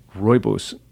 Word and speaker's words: rooibos: And for that, you'll want to give rooibos. I want to say that again. It's R-O-O-I-B-O-S, by the way, if you're trying to phonetically figure that out.